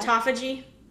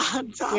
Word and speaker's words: autophagy: Autophagy. – Yeah. Autophagy.